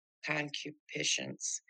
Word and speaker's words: concupiscence: But sin, taking occasion by the commandment, wrought in me all manner of concupiscence, for without the law sin was dead. – Thank you. Very good. Good pronunciation, too. Excellent. A lot of people can't pronounce that word.